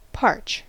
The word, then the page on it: parch: American English